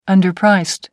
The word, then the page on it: underpriced: American English